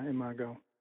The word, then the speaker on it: Imago: Is that how you pronounce that? I always wonder, is it Imago? – Yeah, Imago.